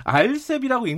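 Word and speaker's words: RCEP: 아 근데 그거 알셉이라고 읽는 게 맞긴 맞아요.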